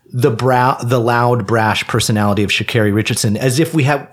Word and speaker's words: Sha'Carri: Start learning how to pronounce her name. It's Sha'Carri.